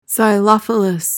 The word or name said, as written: xylophilous